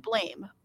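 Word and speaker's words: Blame!: It's spelled like Blame, but apparently pronounced like Blam.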